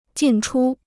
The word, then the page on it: 进出: jìn chū